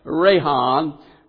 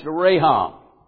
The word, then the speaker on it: racham: It's pronounced Rahan, Rahan, and it's spelled R-A-C-H-A-M, but it's pronounced Rahan. And what it means, His compassions mean His tender love.